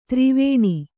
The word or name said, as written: ದ್ರುವಣಿ